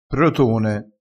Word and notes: protone: dialettale

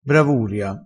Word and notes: bravuria: dialettale

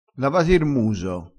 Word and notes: muso: dialettale

